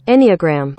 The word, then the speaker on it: Enneagram: Enneagram. – What about that? Let's try a different one. – British accent. – Give me this one. This is the same thing. Okay. – Enneagram.